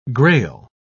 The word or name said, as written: grail